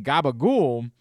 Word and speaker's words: gabagool: The pronunciation gabagool has been used by Italian Americans in the New York City area and elsewhere in the Northeast based on the pronunciation of capicola in working class dialects of 19th and early 20th century Neapolitan. I never knew that.